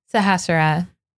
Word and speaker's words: Sahasrara: Sahasrara.